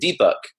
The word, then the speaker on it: Deepak: It was just, and in Hindi, you'd pronounce it Deepak, which means to bring light. And in English, it translates phonetically, D-E-E-P-A-K.